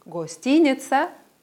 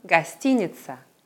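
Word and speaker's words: гостиница: In beiden Wörtern werden die O's wie ein kurzes schwaches A ausgesprochen. Wir sagen also nicht Gostinitsa, oder Hotel, sondern Gastiniza, Hotel.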